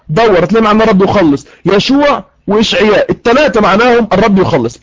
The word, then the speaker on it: يشوع: النقطه رقم 2، النقطه رقم 2، قلنا ان يسوع في العبريه تنطق يشوع.